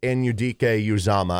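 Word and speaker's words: Anudike-Uzomah: So they end up with Felix Anudike Uzama. I believe that's the proper way to pronounce it.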